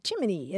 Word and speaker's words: Gemini: the Federal Space Agency's official pronunciation for its new man-in-space program, Gemini, is Jiminy, as in Jiminy Cricket.